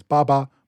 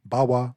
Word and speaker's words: Bawa: The first syllable of her name is definitely pronounced Ba, but historians disagree on how to read the second sign, so you'll see different sources render the goddess's name as Baba, Bawa, Bawu, Ba'u, etc.